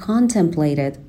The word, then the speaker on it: contemplate: By the summer, though, اگرچه وقتی که تابستون شد, as he contemplated, به تلفظ contemplate دقت کنین, شوا نداره در ابتدا, صدای آ هستش.